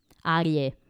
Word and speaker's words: Arye: Sì, ehm, ti dico il nome, Harry, non so se si legge Harry onestamente, A-R-Y-E, sarà Harry. A-R? – Y-E, Arie.